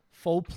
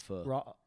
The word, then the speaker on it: pho: Faux place or not faux? – It's pronounced pho.